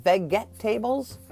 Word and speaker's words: vegetables: V-E-G-E-T-A. B-L-E-S. Oh yeah, it looks like vegetables, but it's pronounced vegetables.